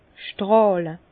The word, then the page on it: stràhle: Bas Rhin; Herrlisheim